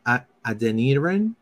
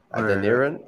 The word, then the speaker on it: Adeniran: How do you pronounce that one? – Adenrian. I guess I want to say Adeniran. – Adeniran. – Yeah, he's like Nigerian.